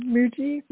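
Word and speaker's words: Mooji: I don't know if everyone has heard of, there's a teacher that goes by the name of Muji. I think that's how you say his name. Muji? – Yes.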